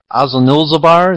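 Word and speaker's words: Azanulbizar: What this reminds me of is the end of the Battle of Azul-Nulzabar. Is that the correct pronunciation? – Yes, yes.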